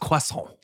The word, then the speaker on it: croissant: No, because a croissant. – It's pronounced croissant. – Okay. – Croissant. We just lost all our French listeners.